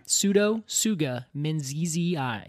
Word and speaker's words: Pseudotsuga menziesii: So the scientific name is Pseudotsuga menziesii. You have any idea what that means? – One more time. – Pseudotsuga menziesii.